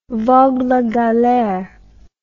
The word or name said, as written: vogue la galere